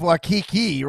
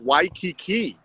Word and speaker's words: Waikiki: I see you're sitting there in the beach of Waikiki right now. – You're still pronouncing it wrong. It's Waikiki.